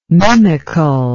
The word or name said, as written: mimical